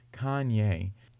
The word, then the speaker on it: Kanye: Oh, Wikipedia gives a pronunciation for Kanye. It's Kanye.